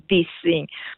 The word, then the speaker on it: dissing: Dissing, con la G finale, che però non si pronuncia relativamente in inglese, è un'abbreviazione dall'inglese per dire disrespect, cioè non rispettare, parlare senza rispetto, criticare, insultare.